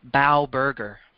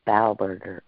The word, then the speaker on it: Bauberger: You said it like most people say it the first time, but I pronounce it and my family pronounces it as Bauberger. – Bauberger.